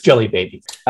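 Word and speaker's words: Djelibeybi: um, one of the city nations is spelled in a really weird way with a D J E L Y E B A B A, but it's Jelly Baby.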